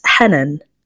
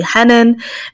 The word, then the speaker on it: Henan: So Belgians usually say Hanan, native English speakers say Hanan, and Arabs pronounce it as Henan. The truth is, I find it a tricky question because I grew up with my family calling me Henan and everyone else calling me Hanan.